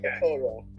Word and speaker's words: Yaphet Kotto: Yaphet Kotto? – Yes. – Kotto.